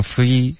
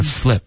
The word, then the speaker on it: flip: free 有点像 free 的发音了应该是 flip OK